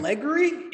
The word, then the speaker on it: allegory: Is film, it like, allegory? – It's allegory. – Allegory. – Eddie's pronunciation game is weak as fuck today. I guess I've just been mispronouncing that forever.